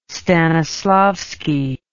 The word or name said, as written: stanislavski